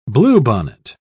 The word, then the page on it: bluebonnet: British English